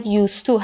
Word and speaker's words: used to: وگرنه تلفظ هر دو مورد used to هست.